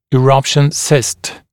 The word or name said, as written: eruption cyst